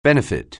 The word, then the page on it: benefit: anglais